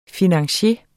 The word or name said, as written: FINANCIERERNES